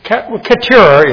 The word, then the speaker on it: Keturah: Keturah is how we usually pronounce it.